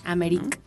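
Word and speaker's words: Ameryk: Americ. Eh, no sé ahí, Americ.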